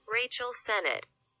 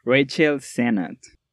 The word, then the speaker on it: Rachel Sennett: Ya para la película se mantuvo la misma actriz para el personaje de Daniel, interpretada por Rachel Sinnott, les debo esa pronunciación, que hace un excelente trabajo actoral. Rachel Sennett. Ojo ahí. Rachel Sennett.